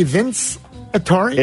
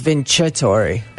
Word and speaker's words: Vincitori: But wouldn't it be Vince Atari? It's whatever they tell us to to call it. I'll check with Chef Bob, but I'm almost positive on there it says it, it phonetically does. that it's vincitori.